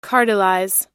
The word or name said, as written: cartelize